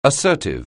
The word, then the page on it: assertive: anglais